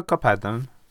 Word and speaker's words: Kapernaum: Hvordan uttaler vi Kapernaum på norsk? Kapernaum? – Ja, Kapernaum.